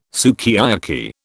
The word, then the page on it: sukiyaki: British English